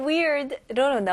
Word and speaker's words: weird: 여기서는 E 모음 소리가 나야 되고요. 중간에 R까지 weird. Weird, okay? 혀가 뒤로 가는 느낌이 꼭 나야 됩니다. Weird라고 하시면 안 되고요. Weird, R를 명확히 해주셔야 됩니다.